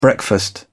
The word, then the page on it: breakfast: British English